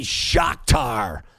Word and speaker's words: Shakhtar: Group C, Man City and FC Shakhtar, as in Tupac Shakur.